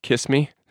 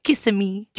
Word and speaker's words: Kissimmee: It's not pronounced Kiss Me? – No. There's definitely another vowel in there. – Kissimmee me? – That you missed. Kissimmee.